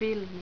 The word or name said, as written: filmi